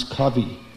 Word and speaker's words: Covey: His name is Stephen, they pronounce Covey, C-O-V-E-Y.